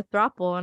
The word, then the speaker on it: throuple: Somebody asked me if I'm in a throuple, and I said, fuck yeah. – Yeah, because it rhymes with couple, so, and it's like three.